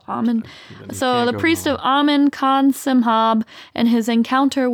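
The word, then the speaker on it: Amun: So a ghost story tells the tale of a high priest of Amen. I think you can pronounce it Amen, Amen. But it's A-M-U-N, so I'm going to say Amen.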